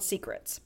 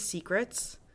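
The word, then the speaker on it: Seacrets: Okay that is called Secrets, but it's called like you know like Seacrest, like Ryan Seacrest.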